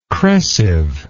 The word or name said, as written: crescive